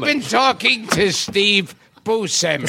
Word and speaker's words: Buscemi: Now, Now, is it how do you pronounce it? You said it the way I say it. – Yeah. – Buscemi.